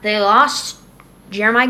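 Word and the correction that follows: Jerami: Grant. – Jeremy. – Whatever. I say Jeremiah because that's how it's spelled.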